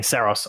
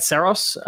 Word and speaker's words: Saros: I've got to correct myself. I've heard too many people mispronounce it over the years. And Greg Loudon in the little presentation that I got, keep saying Saros. I need to say Saros.